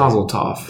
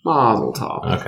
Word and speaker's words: Mazel tov: I don't know how you say it. Mazel tov. – There you go. That's it. – Mazel tov.